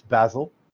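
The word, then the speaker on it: Basel: Um, I like to pronounce it Ball, but it's Basil.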